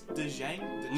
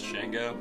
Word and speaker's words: Django: It's Dejang? – No, the D is silent.